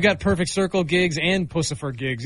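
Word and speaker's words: Puscifer: I heard pussifer, yeah. – Yeah. – All right. – That's how it's pronounced.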